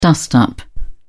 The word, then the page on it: dust up: British English